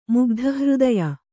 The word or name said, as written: ಮುಗ್ಧಹೃದಯ